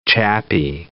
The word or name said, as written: chappie